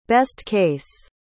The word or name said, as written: best-case